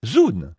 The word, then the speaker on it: zoon: Voyez, ce sont Zoon, Z-2-O-N. Qu'est-ce que c'est? Des êtres vivants. Ça se prononce peut-être Zon, Z-2-O-N. Comment le prononceriez-vous? Zoon? Je dirais Zoon. Et Thérion, c'est T-H-E-R-I-O-N, Thérion, voyez.